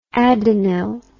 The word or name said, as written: adenyl